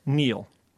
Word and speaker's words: kneel: K-N-E-E-L. Neel. – Raspberry answer. – Silent letters. – Yes, silent letters. Oh, raspberry is... – The silent S... – P. – Well, P. Sorry. – Silent P, right? Or answer. – W. – W and Neel. – Okay. – The K, which was silent until I spelled it earlier in the colloquium.